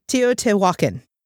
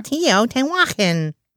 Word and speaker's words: Teotihuacan: Teotihuacan. – That's a pretty good for a Caucasian tongue to say. – Teotihuacan.